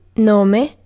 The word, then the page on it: name: /neɪm/